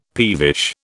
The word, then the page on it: peevish: British English